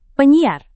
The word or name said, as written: penyiar